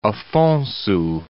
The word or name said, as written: Afonso